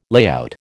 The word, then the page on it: layout: American English